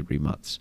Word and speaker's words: Rimac: a merger between Rimac, or as we were pronouncing it last week, Rimac. – Yeah. – But they're Croatian, so it's actually Rimac.